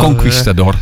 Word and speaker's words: Conquistador: Hoe spreek je het uit? Conquistador. – Conquistador, veroveraar.